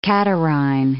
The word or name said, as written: catarrhine